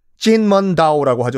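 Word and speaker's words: 진먼다오 (金門島): 자, 금문도. 진먼다오라고 하죠. 중국 발음으로는 금문도.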